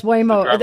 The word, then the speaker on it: Waymo: Well, I have to say, I was telling John Waymo. Is that how you pronounce it? – Oh, yes, Waymo.